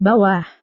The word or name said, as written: bawah